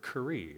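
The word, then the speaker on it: kuri (קרי): So if you look at verse 21, and it reads, yes, if you go against, that go against is that Hebrew word, kuri. It's spelled kaf, resh, yod.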